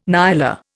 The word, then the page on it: nyala: Indian